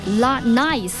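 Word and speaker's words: 来斯: 之前我们在节目当中也跟大家分享到，那么尤其是在一众的热门城市当中，这个江苏南京让来过的人是频频竖起大拇指，脱口而出一句 La Nice。哎，其实呢，这个莱斯呢是这个南京话厉害的意思哈，这发音就特别像这个英文当中的 nice，对不对？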